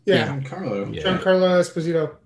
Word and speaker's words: Giancarlo Esposito: Is it Giancarlo? I'm going to butcher his name. – Giancarlo Esposito.